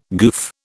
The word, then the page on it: goof: British English